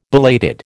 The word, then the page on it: belated: American English